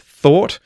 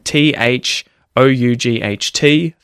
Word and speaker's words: thought: And it is a tricky irregular verb, thought, and it's another one of those words that has GH in it that isn't pronounced, right? T H O U G H T, thought, thought. So the expression food for thought, something is food for thought.